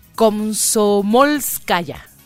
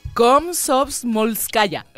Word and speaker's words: Komsomolskaya: Estaciones como, híjole, a ver, vamos a ver si me sale la pronunciación en ruso. A ver, ahí va. Esta estación se llama Komsomolskaya. Así.